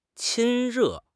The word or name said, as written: 亲热